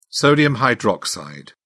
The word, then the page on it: sodium hydroxide: British English